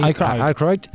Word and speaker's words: Aykroyd: Aykroyd.